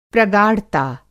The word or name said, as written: प्रगाढ़ता